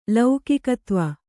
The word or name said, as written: ಲೌಕಿಕತ್ವ